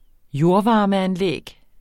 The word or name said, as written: jordvarmeanlæg